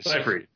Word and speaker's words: Seyfried: There's no G in her name. – Yeah, you're right. – Seyfried.